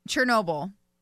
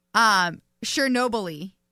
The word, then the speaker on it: Chernobyl: All right, go ahead and pronounce it again. – Chernobyl. Now he says, Chernobyl-y.